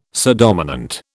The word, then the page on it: subdominant: British English